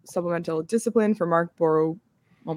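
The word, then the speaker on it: Borowiecki: And I always go with what we called him in the locker room, but clearly we've had mispronunciations in there. It's Borowiczki.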